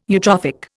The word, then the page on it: eutrophic: Indian